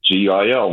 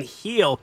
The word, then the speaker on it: Gil: That's a Spanish thing. – You got to appreciate the call. It's just the way you pronounce his name. I don't know. Is that right? I don't know if... – Put it this way. On social media, yes. – When Heel pitches a great game, they put out King of the Heel. – Yes! – If it's pronounced like a G, they wouldn't put it out that way.